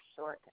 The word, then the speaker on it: Short: It's spelled J-E-N-N-I-F-E-R, short, S-H-O-R-T.